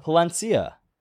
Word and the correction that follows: Plasencia: No, it's Placencia. The S is pronounced. – Palencia. – Just say Pla. – I just said it. – Say Placencia. – Placencia.